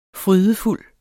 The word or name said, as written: frydefuld